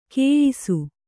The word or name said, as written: ಖಾಯಿಸು